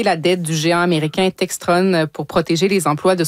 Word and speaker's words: Textron: Textron, il le prononce comme ça en anglais, évidemment.